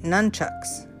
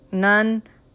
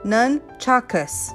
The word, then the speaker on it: nunchakus: He just did this bizarre video of doing nunchucks. Weird. Not nunchucks. Oh, sorry. Nunchakus. Not nunchucks. Don't talk like a gangbanger. They're Okinawan improvised farm weaponry. How do you say it? Nunchakus. That's it.